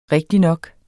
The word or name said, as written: rigtignok